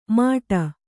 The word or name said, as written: ಮಾಟ